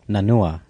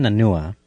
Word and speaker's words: Nanua: And Senia Nauna. Nanua. Sorry, what the fuck did I say? Senia Nanua.